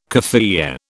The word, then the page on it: kaffiyeh: British English